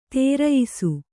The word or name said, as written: ಧರಿಯಿಸು